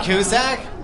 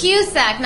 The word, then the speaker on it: Cusack: You don't like John Cusack? – It's Cusack, not Cusack.